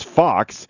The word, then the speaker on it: Fuchs: You know, it's funny, too, like Tommy Fox, so there's a lot of people in my hometown, they call it's F-U-C-H-S, so it's a lot of people in my area would call it Fuchs, but in the Glennon area, it's the German pronunciation, which is Fox, which doesn't look like F-O-X at all, but so there's a lot of that.